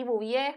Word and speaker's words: Bouvier: con C-E-L-L-I-E. y Bouvier es como March de Los Simpsons.